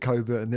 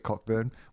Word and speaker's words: Cockburn: But, yeah, I mean, anyone who's got the last name Cockburn knows what we're talking about, and they've pronounced it Coburn their entire life. When your name is actually Cockburn, we all see it written down, but I understand also why you don't want your last name pronounced Cockburn.